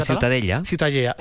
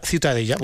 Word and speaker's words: Ciutadella: La Ciutadella. – Ciutadella.